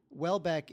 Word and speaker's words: Houellebecq: Welbeck is spelled H-O-U-E-L-L-E-B-E-C-Q. – Mm-hmm. Yeah. – So somehow that becomes Welbeck.